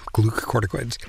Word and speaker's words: glucocorticoids: glucocorticoids. I don't know if I'm saying that correctly. – Yep, glucocorticoids.